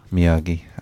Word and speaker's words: Miyagi: Miyagi.